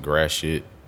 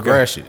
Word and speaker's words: Gratiot: It's because I found out Gratiot is not supposed to be pronounced Gratiot. – Please tell me the correct enunciation. – I'm from Detroit. I ain't doing that shit. It's okay. Gratiot.